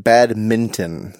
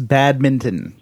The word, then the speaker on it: badminton: I just want to make sure that you are pronouncing it badminton. There is an N there. – Yes, badminton. You're right. – Okay. Because a lot of people just bulldoze right over that N.